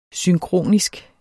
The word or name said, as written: synkronisk